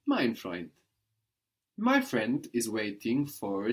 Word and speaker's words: Freund: A friend in German is ein Freund. Ein Freund. – Ein Freund. – This is spelled F-R-E-U-N-D. But of course, the D on the end there is hardened, sounding like a T.